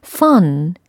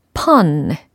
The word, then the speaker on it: fun: 과연 내가 fun이라고 했는지, 펀이라고 했는지 스스로 가슴에 손을 얹고 한번 판단을 해보세요. fun, pun. 발음이 분명 다릅니다.